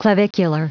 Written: clavicular

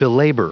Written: belabor

